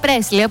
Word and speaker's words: Presley: Suspicious Minds από Elvis Presley ή Presley, όπως προφέρεται κανονικά. Απλώς εμείς το έχουμε συνηθίσει αλλιώς.